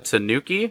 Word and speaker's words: Tanuki: We have the Tanuki.